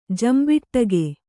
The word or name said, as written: ಜಂಬಿಟ್ಟಗೆ